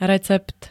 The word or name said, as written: recept